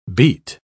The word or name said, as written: beat